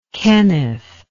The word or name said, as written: Kenneth